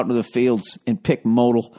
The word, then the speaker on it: modal: It's pronounced modal.